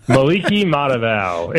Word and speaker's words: Matavao: What about, and correct me on his name, okay, Maliki Matavio. – Matavao. – Say it again. – Maliki Matavao. It's actually pretty, once you get the hang of it, it's actually pretty easy. Just don't look at how it's spelled. Just sound it out, and you're good.